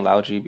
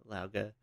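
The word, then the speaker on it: Lao Ge: You know, but it's, you know, it's difficult, I guess, but she's willing to train Kyoshi, which I feel like, so between Lauga, and that's how you say his name, we were saying Lauji before, that assassin guy.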